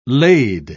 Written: lade

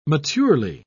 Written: maturely

